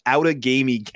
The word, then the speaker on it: Outagamie: That's how that one's pronounced, Outagamie County.